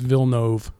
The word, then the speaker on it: Villeneuve: I looked it up before and it's Villeneuve. – Yeah. – That's how it's pronounced.